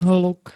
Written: hluk

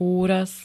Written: úraz

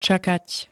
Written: čakať

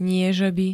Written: niežeby

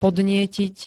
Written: podnietiť

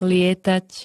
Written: lietať

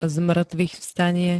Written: zmŕtvychvstanie

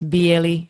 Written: biely

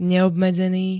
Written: neobmedzený

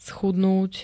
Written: schudnúť

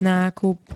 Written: nákup

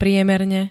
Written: priemerne

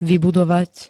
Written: vybudovať

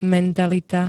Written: mentalita

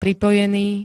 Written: pripojený

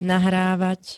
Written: nahrávať

